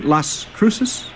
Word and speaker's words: Las Cruces: And Las Cruces, do we say that right? Las Cruces? – Very good. That's exactly right.